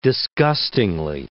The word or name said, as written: disgustingly